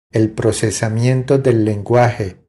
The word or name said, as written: El procesamiento del lenguaje